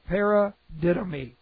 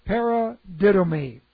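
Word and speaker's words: paradidomi (παραδίδωμι): Para, P-A-R-A, para is a prepositional prefix to the root word didomi. And this is P-A-R-A, that's the preposition prefix, D-I-D-O-M-I, long O there, M-I, paradidomi.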